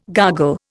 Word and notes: gargle: Indian

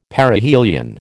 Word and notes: Perihelion: American English

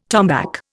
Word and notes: tombac: Indian

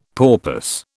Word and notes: porpoise: British English

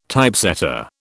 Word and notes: Typesetter: British English

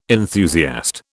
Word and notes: enthusiast: British English

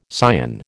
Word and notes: cyan: American English

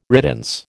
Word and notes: riddance: American English

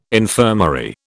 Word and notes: infirmary: British English

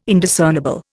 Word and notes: Indiscernible: Indian

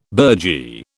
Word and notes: burgee: British English

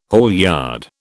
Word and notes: haulyard: British English

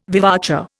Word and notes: vivace: Indian

